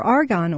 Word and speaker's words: argan: So I just, to get away from all of the other ingredients that possibly parabens or whatever, I just bought pure argan oil. – Now let's be, this is spelled A-R-G-A-N, right? – Argan.